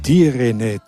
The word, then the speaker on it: Direne: Direne, Direne, so wird das richtig ausgesprochen.